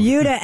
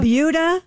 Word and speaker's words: Buda: It's called the Buda. – Buda. – Skip over. – Buda. Sounds like Peter Wolf. – It's spelled B-U-D-A, and I looked it up. It is pronounced like beauty.